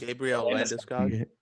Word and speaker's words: Landeskog: He's not as handsome as that Landeskog. How do you say it? – Gabriel Landeskog. – Yeah, there you go.